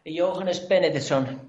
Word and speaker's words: Jóhannes Benediktsson: Jóhannes Benediktsson.